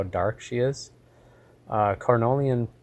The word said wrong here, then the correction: Carniolan: Carniolan, excuse me, I always pronounce that wrong, uh, tend to be very dark bees.